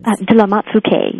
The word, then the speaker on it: de la Motte-Fouqué: Could you please pronounce the Baron? – De la Motte-Fouqué, yes.